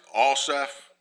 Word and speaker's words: Asaph: In Psalm 73, I looked up how to pronounce this name of this fellow who wrote this psalm. And if I've got it right, it's Asaph.